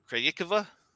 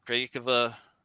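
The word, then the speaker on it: Krejčíková: Listening to the broadcasters, I think it's pronounced Krajicova. I think it's more of the closer name pronunciation.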